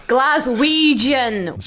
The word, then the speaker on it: Glaswegian: Glaswegian. – So Ouija-jin is a hard G. – Ouija-jin is a soft G. – Or a soft G, Yeah. Glaswegian would be a hard G.